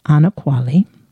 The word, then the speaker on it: Anokwale: And her first name is A-N-O-K-W-A-L-E, pronounced Ana Kwale.